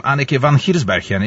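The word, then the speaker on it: Anneke van Giersbergen: Anneke van Hirsbergen, is it right? – Yes, you pronounce it very well.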